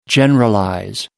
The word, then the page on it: generalize: American English